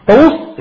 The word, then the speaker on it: طاووس: طاووس عندما تكتب بواوين أم بواحدة؟ واحدة كبيرة؟ أيوة نعم زي داون نعم. فهي تكتب واحدة طاووس وإن كانت تنطق بواوين لكنها تكتب واحدة لكراهة توالي الأمثال.